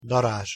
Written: darazs

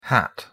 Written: hat

